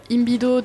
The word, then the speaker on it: Imbido: La première question est assez simple, c'est quoi la compagnie Imbido et pourquoi avoir choisi de l'appeler comme ça? – Alors, Imbido, donc, c'est un mot qui est basque, c'est pour ça que toutes les lettres se prononcent.